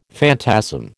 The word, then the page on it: phantasm: American English